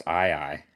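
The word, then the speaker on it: aye-aye: I feel like an AA. It's A-Y-E hyphen A-Y-E. Look him up. It is a long-fingered lemur. – I think it's pronounced I-I.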